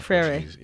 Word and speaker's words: Freire: Pedagogically, we fall under the school of Paulo Freire. I'm butchering that name. – Freire. – Yeah, my Portuguese is trash.